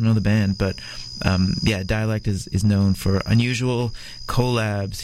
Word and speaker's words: Dalek: So Dalek, I think it's pronounced dialect, but it's D-A-L-A-K. D-A-L-E-K.